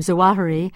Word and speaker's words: Zawahiri: And by the way, I hear you're also saying perhaps the correct pronunciation of his name, Zawahiri.